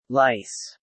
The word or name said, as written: Lice